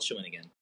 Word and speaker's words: Shawinigan: It's spelled phonetically. Shawinigan.